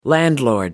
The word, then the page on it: landlord: American English